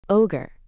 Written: ogre

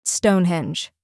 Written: Stonehenge

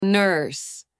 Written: nurse

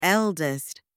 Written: eldest

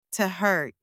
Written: to hurt

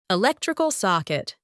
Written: electrical socket

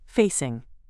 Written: facing

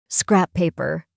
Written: scrap paper

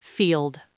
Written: field

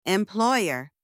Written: employer

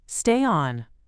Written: stay on

